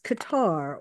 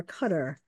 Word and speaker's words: Qatar: Okay, so now let's look at his next stop, which is Qatar or Qatar. I have heard it pronounced both ways, and I will probably interchange my pronunciation of it.